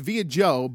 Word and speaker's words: Vieux Diop: It's by a man who has a name that's pronounced via Joe, but it looks like Vo Diop. V-I-E-U-X-D-I-O-P. Two different words.